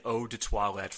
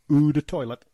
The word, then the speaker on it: Eau de Toilette: WWF Cologne for men and Eau de Toilette for women. And please, you got to pronounce it the correct way. – Oh. – It's Eau de Toilette.